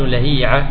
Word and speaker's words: لهيعة: اسم الكتاب تالي مجتبى، نسيت الآن اسم الكتاب بالضبط، يقول إن الأسماء ليس فيها قياس، الاسم ما تقدر تنطقه بالقياس، كيف تنطقه إذن؟ بالسماع. لأننا لا نعرف هل هو عبد الله بن لهيعة لهيعة لهيعة لهيعة، ما نعرف إلا بالسماع. فالمنقول بالسماع هو عبد الله بن لهيعة قاضي مصر.